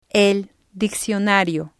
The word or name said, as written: el diccionario